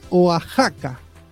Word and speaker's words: Oaxaca: Romer nos dice en el chat: se pronuncia Oaxaca, como México. Puede ser, la verdad, era con X. Y como no escuché nunca, yo lo leí como si fuera Osaka, Oaxaca.